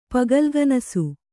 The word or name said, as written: ಪಗಲ್ಗನಸು